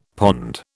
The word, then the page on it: pond: British English